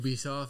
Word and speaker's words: Ubisoft: Nah, but I don't know how to pronounce it. – I've always said Ubisoft. – Ubisoft, that's what I was gonna say too.